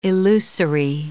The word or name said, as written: illusory